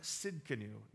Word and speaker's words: Tsidkenu: That's why the Hebrews assign this word Jehovah Tsidkenu. The T is silent in that.